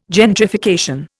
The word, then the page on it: gentrification: Indian